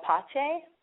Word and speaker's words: Pace: called Pache, P A C E. It's spelled like pace, but it's pronounced Pache, which some people have a problem with, but I love it.